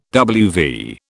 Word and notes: WV: British English

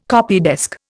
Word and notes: copydesk: Indian

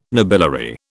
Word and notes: nobiliary: British English

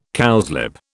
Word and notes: cowslip: British English

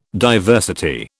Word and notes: diversity: British English

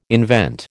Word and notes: invent: American English

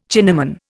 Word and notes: Chinaman: Indian

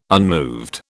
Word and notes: unmoved: British English

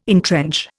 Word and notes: entrench: Indian

